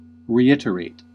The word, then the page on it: reiterate: /riˈɪt.ər.eɪt/